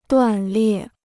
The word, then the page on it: 断裂: duàn liè